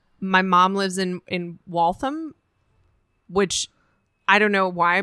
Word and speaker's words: Waltham: Waltham. And it's W-A-L-T-H-A-M. Mm. And I always want to overpronounce Waltham. – It. And be like, Waltham.